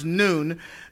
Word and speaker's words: Nun: The word of God comes to us saying, "And Joshua, the son of Nun," and that's pronounced Noon,